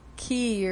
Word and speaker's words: Cay: So Megan's beach there was nice, and then we went to Half Moon Key, or it's spelled yeah. – C-A-Y.